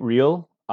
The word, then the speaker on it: Riehl: Last name is spelled R-I-E-H-L. Hello, I am Pete Real, a high school English and Spanish teacher, an avid reader, and an aspiring writer.